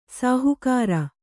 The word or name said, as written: ಸಹಕಾರಿ